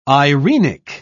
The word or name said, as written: irenic